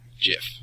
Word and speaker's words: GIF: Actually, I'm sorry. In case he's listening, GIF.